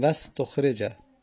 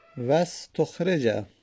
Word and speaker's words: واستخرج: پس میگیم واستخرج، واستخرج. چون که در وسط کلام قرار گرفت، همزه وست تلفظ زده نمیشه.